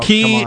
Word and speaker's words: Cay: Staniel Key Divers, or Staniel K Divers, you spell it in the Bahamas, C-A-Y.